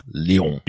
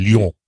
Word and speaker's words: Lyon: Lyon. You must say it like a French person, Lyon.